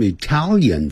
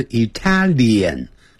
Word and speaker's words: Italian: We will try some Italian food. 我们将要，这是未来时，所以加了这个助动词 will。 We will，当然你也可以变成 we 加一撇加两个 ll， will 也都可以。 We will try 就尝试 some 若干的一些 Italian。我们呢很快会念成 Italian，它发脸的音，哎，不是 Italian 这样念 Italian food，好，一条眼好像念成一条眼，就像一百万啊，常常会听到我们老中念成的 million，不是在美式英语念成 million，没有眼睛。